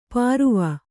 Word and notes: ಪರುವ: paruva

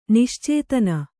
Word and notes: ನಿಶ್ಚೇತನ: niścētana

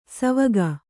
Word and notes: ಸವಗ: savaga

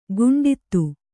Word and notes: ಗುಂಡಿತು: guṇḍitu

